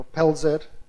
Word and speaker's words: Pelzer: Pelzer se escribe, pero Pelzer creo que se dice.